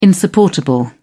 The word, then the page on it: insupportable: British English